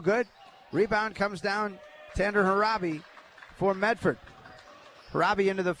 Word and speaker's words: Hraby: Yeah, White, Tanner Raby. Tanner Harabi, I'm sorry. Harabi, H-R-A-B-Y, Harabi.